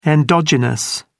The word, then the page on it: endogenous: British English